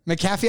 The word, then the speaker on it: McAfee: McAfee, I always called it McPhee. – Well, because it's not MC Fee, it's not MC FEE, it's MC Big A. Big A, McAfee.